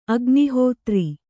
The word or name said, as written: ಅಗ್ನಿಹೋತ್ರಿ